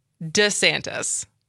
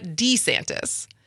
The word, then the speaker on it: DeSantis: I've always just heard DeSantis, not DeSantis. But do we have a clip of him, this going back and forth? – We do.